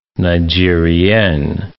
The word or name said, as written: Nigerien